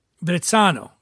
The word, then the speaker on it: Verrazzano: I would think with a double Z, does mozzarella have two Z's? So maybe Verrazzano. – Verrazzano.